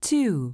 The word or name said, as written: 2